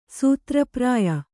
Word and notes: ಸೂತ್ರಪ್ರಾಯ: Kannada

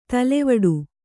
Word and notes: ತಲೆವಡು: talevaḍu

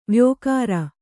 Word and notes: ವೈಯಾಘ್ರ: kannada

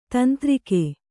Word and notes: ತಂತ್ರಿಕೆ: kannada

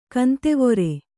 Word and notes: ಕಂತೆವೊರೆ: kantevore

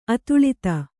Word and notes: ಅತುಳಿತ: Kannada

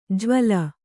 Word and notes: ಜ್ವಲ: Kannada

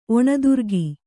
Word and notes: ಒಣದುರ್ಗಿ: Kannada

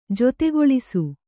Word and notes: ಜೊತೆಗೊಳಿಸು: jotegoḷisu

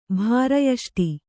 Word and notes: ಭಾರಯಷ್ಟಿ: Kannada

